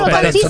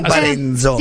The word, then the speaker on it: Parenzo: È che io Parenzo, non Parenzo. – Sì. No, lei lo pronuncia così.